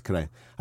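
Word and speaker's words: Correia: If you come from where I come from, it's Correa.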